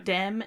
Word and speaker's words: Demme: Wikipedia, it says to pronounce Jonathan it like Demme and then E-E. It's very strange. – Oh, they have Jonathan like phonetically? – Demme. Yeah, they have it phonetically on Wikipedia as D-E-M-E-E.